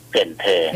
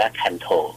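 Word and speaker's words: Cần Thơ: ถ้าลองนึกดูนะฮะมันแทรกเข้ามาถึงเมืองที่ใหญ่ที่สุดจังหวัดที่ใหญ่ที่สุดของภาคใต้เวียดนามคือเกินเธอเกินเธอถ้าอ่านภาษาอังกฤษจะอ่านว่าคันโถ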